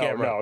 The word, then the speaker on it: Gamrot: Matoush Gamrot. – Oh, no, Gamrot. No. Come on. There have been worse, but you did fantastic with Gamrot. Well done.